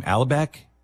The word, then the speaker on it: Alibek: But I want you to tell people the story about what Ken, I can't remember how to say his last name, Alibek, is it? – Yeah, exactly, yep.